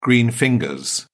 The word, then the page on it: green fingers: British English